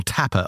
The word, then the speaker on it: Tappe: So that's the work from Oliver Tapp. I'll link to it in the show notes, or Tapper, I should say. That would be the German pronunciation.